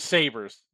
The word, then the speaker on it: Sabre: Sorry, the Sabres. The Sabres against the Capitals? – Yeah, exactly. – I think it's because you're pronouncing it wrong. It's Sabre.